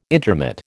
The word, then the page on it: intermit: American English